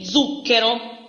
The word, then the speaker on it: zucchero: Z é, podemos dizer que quando é no começo da palavra, se pronuncia Z. Aqui coloquei zucchero, açúcar. – Zucchero. – Sim, exato. Pode ser zucchero, zampa, zio, sempre Z, zucchero.